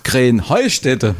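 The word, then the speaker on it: Kreenheinstetten: Ja, ihr sagt immer Krenheinstetten. Und dabei heißt es doch Krähenheustätte, oder wie? – Krähenheustätte. – Ja, genau, und das ist die Betonung auf dem Heu.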